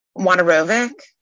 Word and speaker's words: Wojnarowicz: Then the artist David Wojnarowicz. I don't know if I pronounced that correctly. He's a famous queer artist. There was a great Whitney show. – Wojnarowicz, I think. – You know how to say it? – Wojnarowicz. – Okay, thank you.